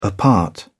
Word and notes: apart: British English

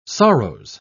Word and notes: sorrows: British English

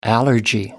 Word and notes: allergy: American English

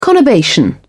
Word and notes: conurbation: British English

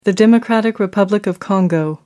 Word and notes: democratic republic of the congo: American English

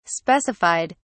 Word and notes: specified: British English

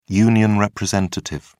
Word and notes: union representative: British English

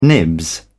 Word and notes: nibs: British English

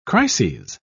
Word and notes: crises: British English

